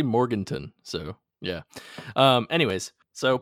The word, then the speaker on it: Morganton: How would you say Morganton? Morgantown. Right. There's no. There's no W.